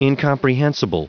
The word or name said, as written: incomprehensible